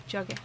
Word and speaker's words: Drogheda: Drogheda.